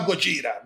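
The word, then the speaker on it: Godira (ゴジラ): Não, não, você pode falar Godira mesmo, porque é assim que se fala.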